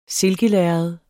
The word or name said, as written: silkelærred